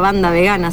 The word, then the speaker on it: Wanda Vegana: Me encuentra como Wanda Vegana, Banda Vegana, se pronuncia en realidad.